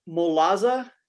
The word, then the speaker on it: Molazo: Molazo. Is that how you pronounce right. – that? – The Molazo. Instead of a Golazo, it's a Molazo.